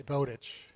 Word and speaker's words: Bowditch: Did I say that right? Is it Bowditch or Bowditch? – I say Bowditch. – You say Bowditch, okay.